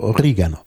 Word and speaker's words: Oregano: Die Standardform wäre Oregano. Ja, auf jeden Fall auf der zweiten Silbe betont. Das ist wichtig.